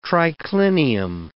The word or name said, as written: triclinium